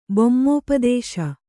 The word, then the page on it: ಬೊಮ್ಮೋಪದೇಶ: bommōpadēśa